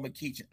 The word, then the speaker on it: McEachin: I actually read an article, and it's and correct me if I'm wrong, is it McEachin? Is that how you pronounce his name? – Yes, Aston Donald McEachin.